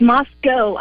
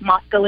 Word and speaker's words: Moscow: Well, it's Moscow, Idaho. – Moscow. – The locals. That's how we pronounce it here.